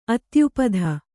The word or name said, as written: ಅತ್ಯುಪಧ